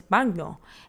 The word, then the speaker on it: bagno: For example, if you're trying to say bathroom, in Spanish it's baño, in Italian it's bagno, and the spelling's about the same, it's b-a-n-o, and in Italian is b-a-g-n-o. So you hear it's like baño, baño.